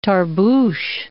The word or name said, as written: tarboosh